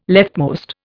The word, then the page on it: leftmost: Indian